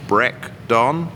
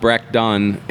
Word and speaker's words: Brecdonn: So wait a minute, it's spelled Brecdonn, and you pronounce it Breakdon? – Not Irish. – Yeah, that's correct. So it's a Gaelic term, pronounced Breakdon, and it mean in Gaelic it translates to brown trout.